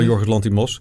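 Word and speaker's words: Yorgos Lanthimos: Van Jorgos Lanthimos. Ja. Waarin spreek je goed uit, dat was ineens zo.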